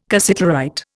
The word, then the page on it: cassiterite: Indian